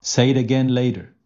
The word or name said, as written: Say it again later